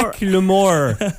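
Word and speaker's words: Macklemore: Non, Michael Moore et Lewis, il faut vraiment que tu sois OK, non, excuse-moi. Pas Michael Moore comme le documentarien. – Mac Lemore.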